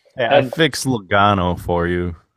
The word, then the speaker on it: Logano: It's Logano. Don't worry, Kevin. You're fine.